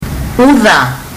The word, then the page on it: uca: fiji